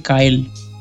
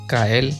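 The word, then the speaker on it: X Æ A-12: desglosó el nombre y dice: X igual a letra griega que se pronuncia ki. Esa A funcionada con la E se pronuncia AI. Y lo de la A-12 corresponde a la letra del alfabeto L. Entonces cuando juntas todo eso dice Kael. Kael. Como el de South Park, ¿viste? Kyle. Ah, Kyle. Sería K-Y-L-E.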